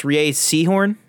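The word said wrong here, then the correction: Rhea Seehorn: Kim Wexler. Rhea Sehorn.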